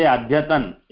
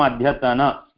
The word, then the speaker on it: अद्यतन: Maudya, because it is not halantam at the end, unlike Hindi, we should not say adhyatan. It will become adhyatana, shvastana, hyastana. This is a slight difference in the way we pronounce in Hindi and Sanskritam.